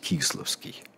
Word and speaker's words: Кисловский: что взрыв состоялся около Малого Кисловского переулка, обязательно кто-нибудь напишет, и это будет последний чат, последняя смс-ка на свете перед концом света, он говорит, надо произносить Кисловский.